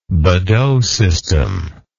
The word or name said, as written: Bedaux system